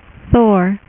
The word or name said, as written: thor